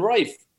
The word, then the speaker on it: Ralph: Now, I hope we don't get millions of listeners saying actually it's pronounced Rafe, because I because we don't often hear that part. I think it's Ralph, but you're no help. You can't... – Ted and Rafe.